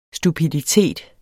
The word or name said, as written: stupiditet